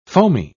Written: foamy

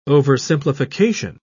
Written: oversimplification

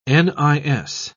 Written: nis

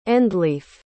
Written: endleaf

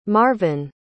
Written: marvyn

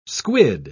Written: squid

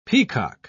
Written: peacock

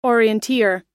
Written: orienteer

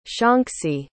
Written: shaanxi

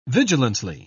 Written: vigilantly

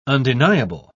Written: undeniable

